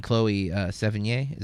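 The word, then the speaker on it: Chloë Sevigny: And then Chloe Sevigny, is that how you say it? – Mm-hmm, mm-hmm. Yeah, I enjoy her performances.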